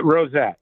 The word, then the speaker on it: Rosette: Am I saying that right, Rob? – Yeah, Rosette. – Rosette. Okay, I think I was maybe making a little French, but it's not French. It's Rosette.